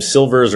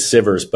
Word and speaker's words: Sivers: I think I'm probably butchering his last name. Silvers, or I think it's Sivers.